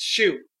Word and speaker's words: Hsu: It was kind of funny because recently, prior to their 200 issues thing, Dan Hsu, is that how you pronounce his name? – No, it's Hsu.